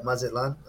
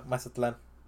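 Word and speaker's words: Mazatlán: Actually, Mazatlan. I'm saying it wrong. – Mazatlan. – Yeah, Mazatlan.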